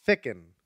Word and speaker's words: Ficken: Ficken. – That didn't help. – Ficken. – Okay, got it. S-A-M-F-I-C-K-E-N.